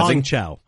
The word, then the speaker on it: Hong Chau: Hong Chau.